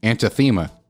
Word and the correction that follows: anathema: Or anathema. Yeah. Anathema. Definitely it. – Anathema. – Anathema. – Yeah, you didn't say that right.